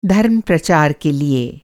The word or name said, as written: धर्म-प्रचार